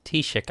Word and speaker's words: Taoiseach: Taoiseach, I think I've pronounced it correctly. Is that right? – Yeah, it's spelled really weird, but it's pronounced Taoiseach, I believe.